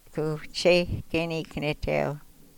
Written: ku cheykeni knetew

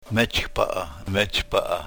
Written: mech paah

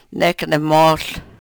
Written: nek ne-mohl

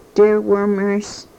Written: trwrmrs

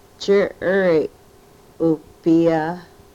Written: chirry u-pyah

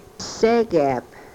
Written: segep

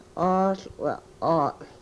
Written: oohl we-ol

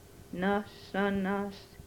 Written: nosonos

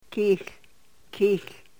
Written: kihl

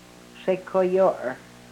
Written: sekoyor

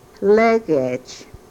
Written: legech

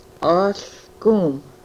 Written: ohlkum

